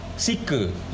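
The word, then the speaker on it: psyche: Jadi kata psikologi itu berasal dari kata Latin sike. Jadi bacanya sike gitu ya. Sike.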